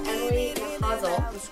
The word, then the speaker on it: Emily Nachazel: um, we have Emily Nachazel. – Yep, you said it right.